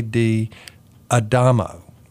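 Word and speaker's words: D'Adamo: Diamano. No, I didn't say that right. J. D. Adamo. It's D apostrophe A-D-A-M-O.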